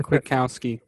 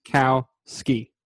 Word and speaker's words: Kwiatkowski: Nick Kwiatkowski. – Kwiatkowski from the Bears. – Yeah, it's the Bears. Kwiatkowski. – Sure. – Thank you. You got to remember quit and then you'll get it. – Kwiatkowski.